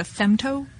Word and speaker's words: femto: And this is on the actually the femto scale. – Yeah, am I saying that right? – That's correct. Yeah.